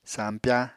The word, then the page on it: sampyá: apatani